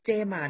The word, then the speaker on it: JMART: สัปดาห์นี้นะครับหุ้นเด่นประจำสัปดาห์ได้แก่บริษัทเจมาร์จำกัดมหาชนนั่นเองนะครับตัวย่อของเขาก็คือ J M A R T อ่านว่าเจมา